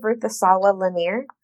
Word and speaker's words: Ruth Asawa Lanier: In today's episode, we'll be listening to excerpts from the oral history of Ruth Asawa Lanier.